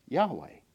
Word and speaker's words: Yahweh: We aren't precisely sure of the vowels, although we kind of think we know, and there's some good reason for the vowels that we choose, but generally we would pronounce that Yahweh, which is I am who I am.